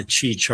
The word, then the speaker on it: Qi: But, and also pronouncing it because it's spelled QI. So it's like, right. Everyone says Qi, Qi. Yeah. And it's like, that's Qi.